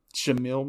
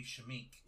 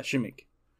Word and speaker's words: Shameik: Shameik Moore? – Shamil Moore? – It's a K, so it'd be Shameik. – Yeah, Shameik.